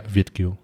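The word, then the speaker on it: Việt kiều: Und ja, Vietnamesen, die außerhalb Vietnams in einer Diaspora leben, werden ähm, hauptsächlich Vietgeo genannt. – Mhm. Habe ich es richtig ausgesprochen? – Ja, Vietgeo ist richtig.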